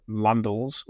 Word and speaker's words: Landels: So it's J-O-N-N-Y. And my name, Landels, although rhyming with candles, is spelled L-A-N-D-E-L-S. So it's an E-L-S rather than L-E-S.